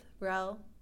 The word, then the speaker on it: Roell: They can find me everywhere, mostly on Twitter, at Elizabeth Rell, just my name, but it's spelled R-O-E-L-L.